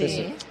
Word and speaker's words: PSE: PSE, ¿no se dice? – Sí, PSE.